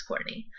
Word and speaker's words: Kourtnea: It's Courtney_Z_H, but my name is spelled KOURTNEA. So that's Courtney.